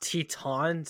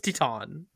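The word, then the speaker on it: Titane: Yeah, well, yes, but in French it's Titan, right? – Everyone was like, French. You're an idiot. It's Titan. – It's Titan, but it's because it's French.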